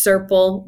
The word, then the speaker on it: Serpell: And it's not circle with a C. It's Serpell, rhymes with purple.